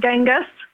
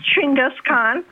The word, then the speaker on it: Chinggis Khan: Chinggis Khan, by the way, is not Genghis. If you talk to a Mongol, they won't kill you. They won't kill you. They'll be very upset at you for saying it. It's actually pronounced Chinggis Khan.